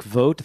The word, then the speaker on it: Vogt: Father Emmerich Vogt, that's spelled V-O-G-T. It's the German spelling.